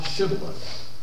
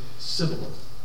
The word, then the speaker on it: Shibboleth: If they said no, then they would say to him, say now, Shibboleth. But he would say, Sibboleth. Couldn't get the pronunciation out. Couldn't get that S-H-sh, Shibboleth out. Sibboleth.